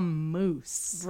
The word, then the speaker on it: Moos: My dad and his brother, or our dad and our uncle, decided that they would no longer be a moose. – Right. – A Bullwinkle. M-O-O-S without the E. – Yes. Which in German, to be fair, is pronounced Moos.